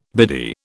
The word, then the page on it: biddy: British English